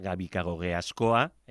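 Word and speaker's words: Gabikagogeaskoa: Mario Iceta Gabikagoge Ascoa, he pronunciado bien el segundo apellido, ¿no? – Sí, muy bien.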